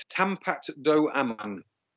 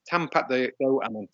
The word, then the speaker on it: Tampat Do Aman: It's Tampat Do Aman. Tampat Do Aman.